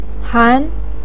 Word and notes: han2: pinyin

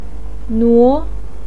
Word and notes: nuo2: pinyin

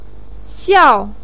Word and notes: xiao4: pinyin

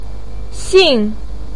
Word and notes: xin4: pinyin

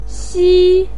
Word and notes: xi1: pinyin

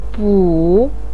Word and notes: bu3: pinyin

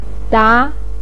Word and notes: da2: pinyin

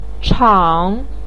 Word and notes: chang3: pinyin

